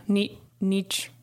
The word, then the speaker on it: Nietzsche: So this one comes to us from Friedrich Nietzsche. – Nietzsche? – Yeah, Nietzsche.